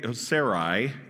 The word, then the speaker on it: Sarai: Then God said to Abraham, regarding your Sarah, your wife, her name will no longer be Sarai, is actually how it's pronounced, will no longer be Sarai.